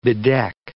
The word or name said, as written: bedeck